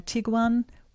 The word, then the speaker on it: Tiguan: Afternoon, I'd like to replace my Tiguan with a, is that how you say it?